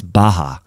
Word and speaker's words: Bacher: This guy's name is Franz Baha. It's spelled B-A-C-H-E-R, but it's Baha.